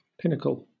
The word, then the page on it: pinnacle: British English